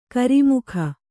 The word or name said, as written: ಕರಿಮುಖ